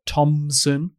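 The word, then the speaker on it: Thomson: Apparently the way that my surname is spelt, Thompson, with no P, apparently that's Scottish.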